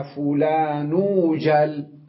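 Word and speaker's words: ايجل: فإيجل لو جاء قبلها كلمة حرفها الأخير مضموم قبل همزة الوصل في إيجل يجب أن تقلب الياء واوا في النطق، لكنها تبقى في اللفظ مكتوبة ياء، فهنا نلفظها واوا ونكتبها ياء، فماذا نقول؟ نقول مثلا يا زيدوجل، يا فلانوجل، لاحظوا فلانوجل، إذن الياء لفظناها واوا لكنها بقيت بالكتابة وبالخط ياء إيجل.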